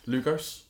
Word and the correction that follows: Lugol's: Lugol's iodine uh, from Clive Store.